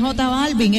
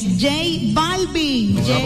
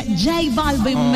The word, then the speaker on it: J Balvin: No, locutor feliz, no es J Balvin, es J Balvin. – Pero muéstrame ahí cómo se escribe, muéstramelo ahí. – J Balvin, por eso, pero se pronuncia J Balvin.